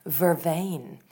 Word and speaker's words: vervain: I don't know, I'd have to Google it, but I think Vervienne, and I'm sure I'm mispronouncing it in the same way I mispronounce croissant, but I feel like that might be, tea infusion. News just in, it's pronounced vervain.